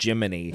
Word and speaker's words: Gemini: And it's so funny that they made a steampunk Jiminy Cricket by writing it as Gemini. – Yes. – Like a computer AI voice thing would be, but it's still just pronounced Jiminy.